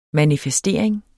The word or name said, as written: manifestering